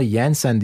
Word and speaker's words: Jensen: Bu arada bir arkadaş, ya onun ataları İskandinav, aslında Jensen diye okunuyor dedi. Benim gördüğüm videolarda Jensen diyorlardı. Ama Jensen de olur.